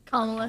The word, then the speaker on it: Kamala: Okay, well, on that too, Kamala Harris, how do you say her name? – Kamala Harris.